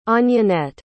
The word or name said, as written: Anjanette